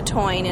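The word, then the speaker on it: Táin: But anyway, if I say it wrong, but she pronounced it the Toine, and I've said it that way.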